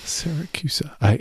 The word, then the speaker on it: Siracusa: Siracusa.